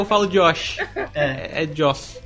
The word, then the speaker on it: Joss: Por que, que vocês falam Josh se é Joss? Não sei. Não sei. Agora pode seguir, pode pensar, seguir. Mas, mas, a é pronúncia é, né, é, Josh? É não é Josh. – Não? Não. É tipo Joss Stone, sacou?